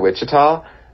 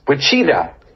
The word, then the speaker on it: Wichita: Murray Hill, I was driving with Murray Hill, and Murray just looked at the sign and he goes, Wichita, Wichita. And I was like, oh, good Lord. Do you think it's called Wichita, Kansas? – he put the emphasis on the wrong syllable.